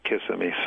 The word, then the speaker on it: Kissimmee: is it Kissimmee or Kissimmee, Florida? – Well, I guess it's depending on where you're from, but I say Kissimmee, so.